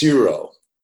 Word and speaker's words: gyro: What it looks like is gyro, but it's like the G isn't pronounced with the J. It's like more like an H, Iro, or right.